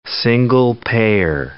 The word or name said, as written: single-payer